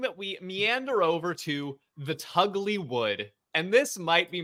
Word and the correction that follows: Tulgey: It's or sorry, Tulgey Wood. I might have the pronunciation wrong. It's T-U-L-G-E-Y.